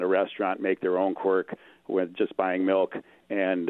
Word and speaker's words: Quark: In your travels to Europe, did you ever come across a real young cheese called Quark, or it's spelled Q-U-A-R-K, Quark, I guess, if you wanted to English it.